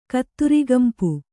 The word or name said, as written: ಕತ್ತುರಿಗಂಪು